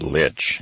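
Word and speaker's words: Lich: It's L-I-C-H. I think it's pronounced Litch, and some people say Lick, but it was a gentleman named Litch, a 46-year-old man.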